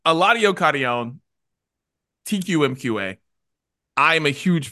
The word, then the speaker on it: Eladio Carrión: Eladio Carrión.